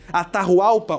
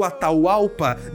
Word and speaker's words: Atahualpa: Problema é que um outro filho do grande Juana Capac, Atahualpa ou Ataualpa, dependendo da pronúncia, se você quiser ser mais espanhol ou mais ligado às comunidades originais, ele era o cara que comandava o exército imperial que estava lá em Quito.